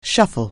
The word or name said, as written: shuffle